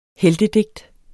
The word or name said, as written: heltedigt